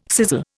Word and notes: sizzle: Indian

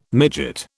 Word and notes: midget: British English

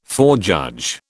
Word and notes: forejudge: British English

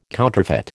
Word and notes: counterfeit: American English

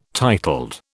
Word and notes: titled: British English

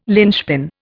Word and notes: lynchpin: Indian

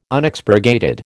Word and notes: Unexpurgated: American English

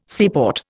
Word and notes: seaport: Indian

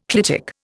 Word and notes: clitic: Indian

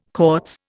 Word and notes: Cortes: Indian

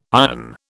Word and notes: ion: British English

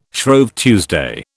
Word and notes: Shrove Tuesday: British English

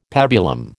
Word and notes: pabulum: American English

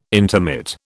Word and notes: intermit: British English

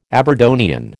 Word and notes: Aberdonian: American English

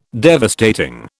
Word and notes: devastating: British English

